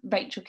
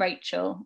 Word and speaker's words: Rachael: On LinkedIn, Rachel Kenny, Twitter is Events with Rach, and then Instagram and TikTok is Events with Rachel, which is spelled AEL at the end. We've got two A's in my name. – Oh, yes. Yeah. I'm with you. – R-A-C-H-A-E-L.